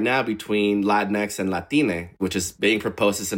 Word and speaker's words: Latine: And really quickly, Latine, as sounds exactly how it's spelled, L-A-T-I-N-E, and it's coming from South America.